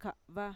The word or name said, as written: ka'va